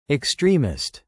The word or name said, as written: extremist